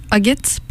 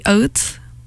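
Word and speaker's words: Ağıt: I don't remember any lyrics being in the song, but the artist is Turkish and the title is Agit, but like if I like think Turkish, it would be Oat, because like the G would be the silent G with a line on top of it, which makes the previous letter longer.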